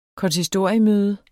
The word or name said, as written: konsistoriemøde